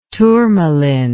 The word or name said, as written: turmaline